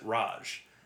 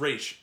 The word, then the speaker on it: Ra's: And, and, everything except the animated series, they always pronounce it Raj. – Yeah. – And in the show, in that show, it's Raish.